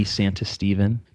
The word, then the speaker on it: Santistevan: Twitter as well, yeah, at D Santistevan.